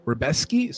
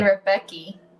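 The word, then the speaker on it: Rebecki: It's tough because the name looks like Rebecca, but I guess it's pronounced Rebeski. So Matuz Rebeski. Yeah, I've been saying Rebeki. – I think everyone says Rebeki until, like, yeah, I was saying it until somebody corrected me.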